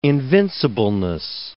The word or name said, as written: invincibleness